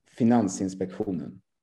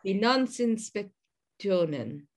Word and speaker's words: Finansinspektionen: Finansinspektionen. Oh my Gosh. – Finansinspektionen. – You need to say it at sort of, you need to, you know, go down when you say it as well. Finansinspektionen.